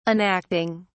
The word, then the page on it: enacting: British English